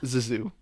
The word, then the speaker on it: Zissou: It, like, I try, but it's like, pronounce Zissou. The two S's like a Z. – Yeah. – Zissou.